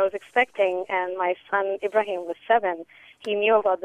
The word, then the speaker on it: Ibrahim: His name is Ibrahim, which is often mispronounced as Abraham. And he always corrects people that it's Ibrahim, the Prophet Ibrahim, the father of all three Abrahamic faiths.